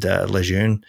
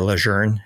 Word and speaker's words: Lejeune: If you haven't heard of it, it was founded by one of these kind of well-known guys named Lejeune, or Lejeune, if you want to say his name correctly.